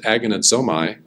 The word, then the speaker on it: agonizomai: That is how I'm going to pronounce it, agonizomai.